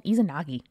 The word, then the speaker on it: Izanagi: And this is episode 95 Izanami and Izanagi.